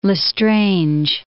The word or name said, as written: L'Estrange